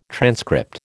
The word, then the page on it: transcript: American English